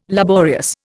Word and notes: laborious: Indian

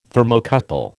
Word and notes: Thermocouple: American English

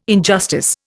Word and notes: injustice: Indian

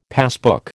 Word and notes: passbook: American English

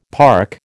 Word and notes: park: American English

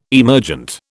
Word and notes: emergent: British English

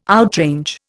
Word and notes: outrange: Indian

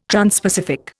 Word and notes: transpacific: Indian

